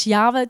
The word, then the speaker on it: Yahweh: Maar wat we wel weten is dat voor de Joden deze naam eh, zo heilig was dat ze het uit eerbid niet wilden uitspreken. En het staat wel algemeen bekend als Yahweh, dus zo zal ik het ook zeggen.